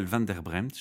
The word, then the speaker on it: van der Brempt: Et euh, Chantal van der Bremt, je prononce bien ton nom de famille. Oui, c'est tout à fait ça, c'est bien.